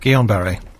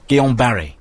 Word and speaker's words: Guillain-Barré: Guillain-Barré syndrome, is that correct? – Guillain-Barré. – Guillain-Barré. – Yeah. – Is that the anglicised way of pronouncing it? Barry? I don't know. – I'm told that officially that's the phonetic pronunciation.